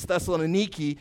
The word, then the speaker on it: Thessaloniki: It's pronounced a little different. It's Thessaloniki.